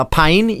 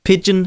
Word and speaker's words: Pijn: Pigeon. Det, ja. udtales, det staves P-I-J-N. Så øh, jeg har nogle gange kaldt dem pine, fordi det, det sådan, man vist ville udtale det, hvis de var hollandske.